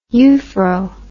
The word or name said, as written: uphroe